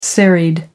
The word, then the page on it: serried: American English